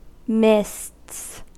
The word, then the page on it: mists: American English